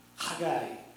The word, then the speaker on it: Haggai: Some people pronounce it Haggai, but we happen to have a professor of Hebrew sitting on the front row. So, how do we pronounce Haggai? – Haggai. – Okay, that's how you pronounce that.